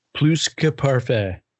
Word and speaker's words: Plus Que Parfait: But I think the value could be the four horse Plouquet Parfait, I think is how you pronounce his name. – Plus Que Parfait. – Plus. So you do pronounce the S, huh? – Plus.